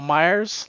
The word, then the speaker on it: Myers: My name is Michael Myers, like from Halloween, even spelled the same.